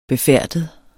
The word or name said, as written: befærdet